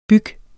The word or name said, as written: byg